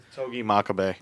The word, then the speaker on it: Togi Makabe: So the first match of the G1 Climax 28 had, I can't pronounce his name, was it Togi Makambi? – Togi Makabe.